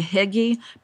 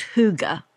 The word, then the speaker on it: hygge: H-Y-G-G-E. Yeah. I always, to be honest, say hygge, but I think the real pronunciation is hygge.